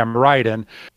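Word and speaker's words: Miraidon: Nothing against Mirrodon, or I think that's Mirrodon. How you pronounce it. Yeah, Mirrodon.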